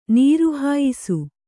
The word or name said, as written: ನೀರುಹಾಯಿಸು